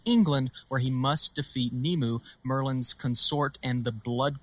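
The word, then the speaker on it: Nimue: From Stranger Things has been cast to play Hellboy, while Mia Jovovich to bring Nemu. Am I saying this right? – Yeah, Nemu.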